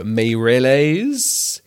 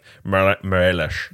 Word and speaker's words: Meirelles: I mean, to be honest, he's Brazilian, so it'll be like Morelles.